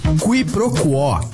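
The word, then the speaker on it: quiproquó: Quiproquo. Q-U-I-P-R-O-Q-U-O com acento agudo. Quiproquo, também informalmente pronunciada como quiproquo, é a ação de confundir uma coisa com outra, ou o desentendimento decorrente dessa confusão.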